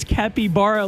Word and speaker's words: capybara: And there's a bunch of videos on TikTok of people owning a capybara. – Capybara. – It's spelled C-A-P-Y-B-A-R-A.